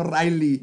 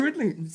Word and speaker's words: Ridley: Es como Ridley Scott, no Riley.